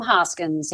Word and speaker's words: Hoskins: My name is spelled H-O-L-L-Y-N-D. Last name Hoskins, H-O-S-K-I-N-S, Hollynd Hoskins.